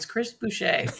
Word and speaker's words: Buescher: You guys all pronounce it wrong. It's Chris Buescher.